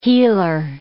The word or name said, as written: healer